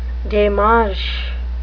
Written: demarche

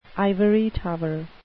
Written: ivory tower

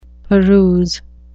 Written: peruse

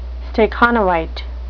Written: stakhanovite

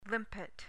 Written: limpet

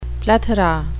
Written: plethora

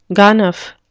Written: ganef

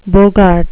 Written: bogart

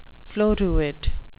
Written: floruit

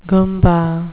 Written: goombah

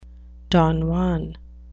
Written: don juan

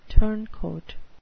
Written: turncoat

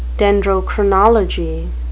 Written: dendrochronology

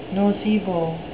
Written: nocebo

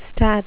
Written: stat

